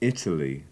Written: italy

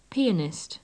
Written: pianist

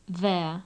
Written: there

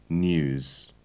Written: news

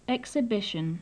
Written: exhibition

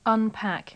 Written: unpack

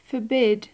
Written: forbid

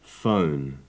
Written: phone

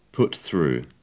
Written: put through

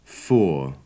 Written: four

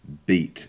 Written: beat

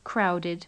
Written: crowded

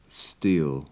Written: steel

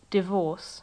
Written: divorce

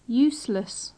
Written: useless

